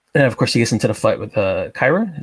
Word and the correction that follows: Karai: Is that how you pronounce it? – Or it was Karai. Thank you. I was pronouncing that wrong.